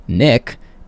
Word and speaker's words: Nic: You might not know, but DJ Sam I Am, Sam is not my name, but my name is actually Nick, and it is spelled just N-I-C, and I am gonna argue here that N-I-C is the proper spelling of a shortened name of Nicholas, because there's no K in Nicholas.